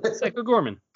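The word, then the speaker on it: Psycho Goreman: Psycho Gorman.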